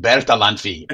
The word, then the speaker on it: Bertalanffy: His name is Ludwig von Bertalanffy.